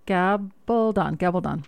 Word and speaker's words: Gabaldon: Gabaldon.